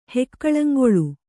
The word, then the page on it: ಹೆಕ್ಕಳಂಗೊಳು: Kannada